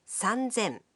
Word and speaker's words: 三千: Here too, the pronunciation changes. 3,000 becomes 三千. 8,000 becomes 八千.